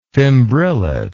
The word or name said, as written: fimbrillate